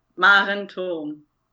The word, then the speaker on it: Maren Thom: Maren Thom.